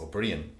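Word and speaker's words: Ó Briain: Or what I get is more, I suppose, people saying you're Liam O'Brien, even though it's spelt O'Fada B-R-I-A-I-N. I say, no, it's O'Brien.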